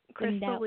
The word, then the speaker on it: Krystal: Krystal Lee with a K, I always tell people, because my parents decided they wanted to spell it a little funky.